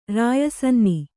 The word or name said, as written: ರಾಯಸನ್ನಿ